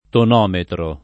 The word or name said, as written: tonometro